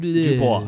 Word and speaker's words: Dubois: I Googled it. I said, how do you say this? And they were like, Dubois. And I was like, got it.